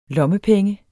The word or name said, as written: lommepenge